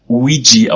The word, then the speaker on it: Ouija: Es wird tatsächlich Luigi ausgesprochen, wie auch immer der E-Laut da am Ende reinkommt. Denk an Luigi aus äh, Super Mario Bros. und nimm das L weg. So wird das ausgesprochen.